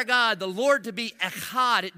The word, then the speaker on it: echad: The Hebrew word is echad. Say echad. Okay, you didn't have enough in it, all right? Seriously, man, Hebrew's got it. I mean, it's a guttural language, all right? If you say it right, then your neighbor in front of you should know. It should get on him a little bit, all right?